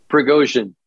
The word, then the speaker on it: Prigozhin: What happened here is it Pirogin? I call him Pirogin like pierogi, whatever his name, the insurrection leader. How do you pronounce his name? – Prigozhin. – Not Prigozhin, not pierogi.